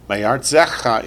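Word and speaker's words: Me'artzecha (מארצך): Ve'ata, yatsata, how do you say, from your land? – Me'artzecha is correct. Me'artzecha.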